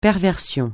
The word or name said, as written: perversion